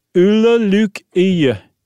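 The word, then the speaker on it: Ole Lukøje: Ulla Luk Ie. – Wow. – And it's not dead on, but it's okay.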